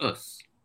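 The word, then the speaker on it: us (ус): And I call these species collectively, first I'll spell it, YC. And it's pronounced us.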